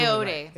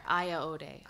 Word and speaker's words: Ayoade: So hopefully Ayoade. – Right. Okay. We're going with Ayoade. She remembered. I didn't. – You have to put an extra syllable in there. Ayoade.